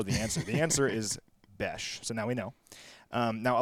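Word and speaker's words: beş: The answer is it is pronounced Besh. Besh. And Larry, Besh.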